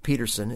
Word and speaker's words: Pedersen: This is Chris Hillman and his wonderful friend Herb Peterson. It looks like Pedersen, but it's pronounced Peterson, believe me, because Herb has corrected me.